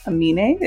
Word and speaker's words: Aminé: I think the artist's name is Aminé. Aminé, is that his name, y'all? – Yep.